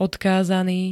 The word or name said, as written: odkázaný